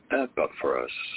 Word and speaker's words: Epaphras: Epaphras.